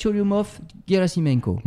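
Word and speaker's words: Tchourioumov-Guérassimenko: Et puis, il n'y a pas que des astéroïdes et des planètes, il y a aussi des comètes comète Cholyumov Gerasimenko. Vous prononcez très bien.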